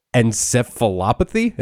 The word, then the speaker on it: encephalopathy: And this film centers around CTE, chronic traumatic encephalopathy. Am I saying that right, Patrick? – Well done. You got it.